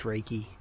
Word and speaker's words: Reiki: I actually always want to pronounce it Reiki, but it's Reiki.